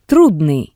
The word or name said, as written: trudnii